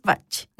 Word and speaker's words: Bach: La correcta forma de decirlo, y como a él mismo le gustaba que pronunciaran su nombre, es Bach, de origen galés.